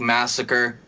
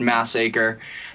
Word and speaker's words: Massacre: It's spelt like massacre, but it's pronounced massacre.